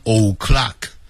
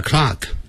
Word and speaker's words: o'clock: 那个不要念成 o'clock, 那个 o 啊要念成 a. 1 o'clock, sharp.